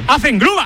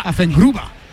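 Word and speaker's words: Affengruber: Afengruber. – Afengruber.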